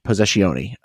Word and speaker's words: Possessione: For Possession. Possessione. The correct Italian way to pronounce it.